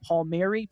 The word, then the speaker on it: Palmieri: You were 99.9% of the way there. You're just going to throw the I on the end. Palmieri, perfect.